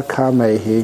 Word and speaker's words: kamehi: Vivicha kamehi, vivicha akusalehi damehi. Vivicha means separated from, apart from. And again, the kamehi there, K long A M E H I.